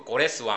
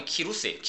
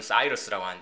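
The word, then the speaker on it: 키루스예요 (Κῦρος): 이러든 그 고레스 왕, 우리 성경에는 고레스 왕이라고 하는데 이거 정확히 발음하면 키루스예요. 키루스 2세입니다. 예, 영어 쓰는 사람들은 사이러스라고 하는데 이 사이러스 그 틀린 발음입니다.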